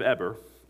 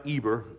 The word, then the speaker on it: Eber: Also to Shem, the father of all the children of Eber, it's not Eber, it's pronounced Eber, I learned, and the older brother of Japheth, children were born.